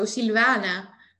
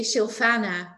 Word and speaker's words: Silvana: It's Italian originally, so Silvana. And in Dutch, they say Silvana, which is pretty ugly, but okay.